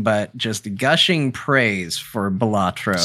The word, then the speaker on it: Balatro: We also played Balatro, is what I've been told is how it's pronounced.